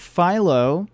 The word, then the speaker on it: Philo: He serves on the editorial board of Philo. Am I pronouncing that correctly? – That's correct.